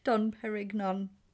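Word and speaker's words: Dom Pérignon: Dom Perignon. And he's like, yeah, that's that's how they say it in France.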